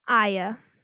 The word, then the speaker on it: Aiya: Aya. – And how do you spell that? – A-I-Y-A.